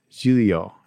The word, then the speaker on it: Giglio: And then the O is like a O. Giglio. Giglio. Okay, I think they got him.